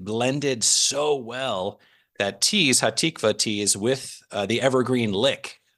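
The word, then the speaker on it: Hatikvah: Earlier in set one, during Evergreen, which is just a really sweet kind of nice tune, but you'll have to help me with the pronunciation, Catholic turned Lutheran over here, but in about 5:35 into Evergreen, is it Hatikva? – Yep, Hatikva.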